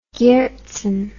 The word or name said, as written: Herzen